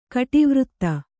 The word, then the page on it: ಕಟಿವೃತ್ತ: kaṭivřtta